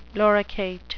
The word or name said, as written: loricate